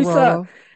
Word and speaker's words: saguaro: said, so I said saguaro cactus, and he's like, Saguaro.